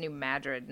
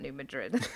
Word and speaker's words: New Madrid: And there may be some at least minor damage in the far east end of the state from a big New Madrid event. So it has the attention of a lot of people that I know. – See, John, I told you it's New Madrid, not New Madrid.